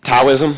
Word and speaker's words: Taoism: It's actually Taoism. – Taoism? I like Tao. Tao. So, Taoism. And why it's called that, I don't know. But it's probably nullifies your question because I pronounced it wrong.